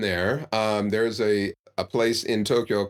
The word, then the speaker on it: Tokyo: A lot of people will say, for example, they'll pronounce the Japanese capital with three syllables. It only has two. Tokyo. And it's a long, the to is long. Tokyo. The kyo is shorter than the o in to, Tokyo.